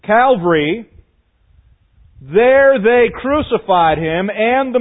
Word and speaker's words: Calvary: You commonly hear it referred to as what? Calvary. Not cavalry riding on a horse. Cavalry. Okay? I said it wrong. You know what I mean. There's a difference between cavalry and Calvary.